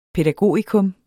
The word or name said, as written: pædagogikum